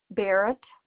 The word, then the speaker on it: Barrett: Yeah, last name is Barrett, and zip code is 48848. – Thanks. Could you say your last name for me? – Barrett with a B. – B-A-R-R-E-T. – B-A-R-R-E-T-T.